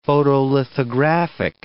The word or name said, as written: photolithographic